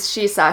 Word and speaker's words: shisa: The Ryukyuan pronunciation of shishi, or another pronunciation of Koma Inu, right, is shisa, shishi, shisa.